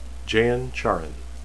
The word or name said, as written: jain